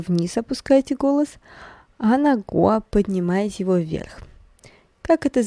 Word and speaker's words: 国: «го» или готя это страна. «Го» произносится вторым тоном – а вот «во» третьим.